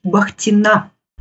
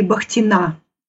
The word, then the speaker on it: Бахтина: Дело в том, что моя фамилия звучит Бахтина. – Будем знать. – Конечно, очень-очень уважаю Баха, но ударение лучше ставить на последний слог. Это от архангельской фамилии Бахтина.